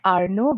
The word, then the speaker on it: Arno: So my first name is Arno. I know it reads A-R-N-O like Arno, but in Bengali, it's pronounced as Arno.